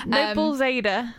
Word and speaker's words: Noblezada: We were just having an extensive discussion about how you pronounce her surname. – Noblezada. – Yeah, I would go Noblezada. I quite like the idea of it being Noblezada, which sounds very like Zorro almost.